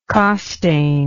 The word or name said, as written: costain